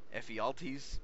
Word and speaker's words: Ephialtes: The individual's name was Ephialtes. I might not be saying that right, because obviously that's good. – Ephialtes is a traitor and is actually still the Greek word for nightmare.